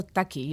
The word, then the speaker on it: Taki: Sí, originalmente al parecer eh, habían escrito taque con E, pero pronunciado taqui.